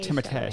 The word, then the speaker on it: Timothée: Timothée Chalamet.